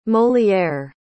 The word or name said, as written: moliere